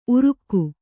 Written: ಉರುಗು

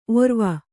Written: ಔರ್ವ

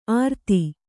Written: ಅರ್ತಿ

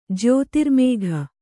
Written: ಜ್ಯೋತಿರ್ಮೇಘ

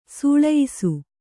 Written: ಸೂಳಯ್ಸು

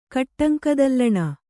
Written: ಕಟ್ಟಂಕದಲ್ಲಣ

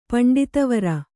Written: ಪಂಡಿತವರ

